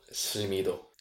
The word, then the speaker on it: Silmido: Then there's also the film Silmido, or how do you pronounce that? – Silmido.